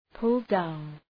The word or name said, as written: pull down